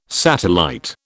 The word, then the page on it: satellite: British English